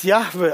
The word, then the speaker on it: Yahweh: Heutzutage werden diese Buchstaben als Yahweh ausgesprochen. Aber es ist nicht ganz deutlich, wie der Name ausgesprochen wird, weil es ja nur vier Konsonanten sind. Aber so wird er heutzutage ausgesprochen und der Name bedeutet zuerst, ich bin der ich bin.